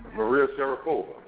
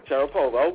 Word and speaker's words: Maria Sharapova: Maria Sharapova, and I know I'm pronouncing that wrong, as I don't know what, but... – Nah, it's Maria Sharapova. – Sharapova, okay. – Yeah, that's right.